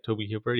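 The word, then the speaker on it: Tobe Hooper: No, that's how you say his name, right? Toby Hooper.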